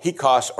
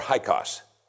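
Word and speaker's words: Hyksos: A new group of people called Hikos or Hikos, depending on your pronunciation, they changed Egypt.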